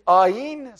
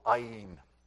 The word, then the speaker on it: Ayin: Ayin has no counterpart, by the way, in our alphabet. In fact, you don't actually pronounce it when you're speaking Hebrew. In fact, it actually is unpronounceable, Ayin, at least by us non-Eastern speakers. It's kind of like a catch in your throat before a vowel comes out, let alone a consonant.